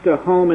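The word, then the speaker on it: tahom (תהום): But in the second verse of Genesis, this word abyss, pronounced tahom in the Hebrew, simply refers to the primeval ocean.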